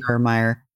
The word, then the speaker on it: Garmire: I go by Sandy Lane Garmeyer.